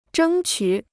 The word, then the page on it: 争取: zhēng qǔ